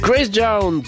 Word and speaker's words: Grace Jones: Grace Jones, ouais, c'est comme ça que ça se prononce.